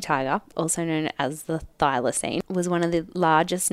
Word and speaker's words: thylacine: But these are just like the facts, right, about the thylacine. Is that how you say it? – I think so. I've always said it that way.